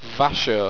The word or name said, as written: vasho